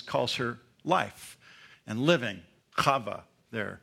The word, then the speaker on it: Chava: In the Hebrew, it's, you might have even heard this before, Chava. Chava is the spelled with a K-H, the guttural thing, the Arabic sound, Chava.